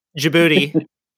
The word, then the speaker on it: Djibouti: Djibouti.